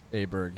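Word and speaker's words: Åberg: We're going to get a better pronunciation. I believe it's like Oberg. – Yeah. – Is that right? – But he introduces himself as Aberg.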